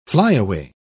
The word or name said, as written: flyaway